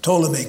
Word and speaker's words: Ptolemy: Ptolemy, the P is silent, Ptolemy claimed Egypt.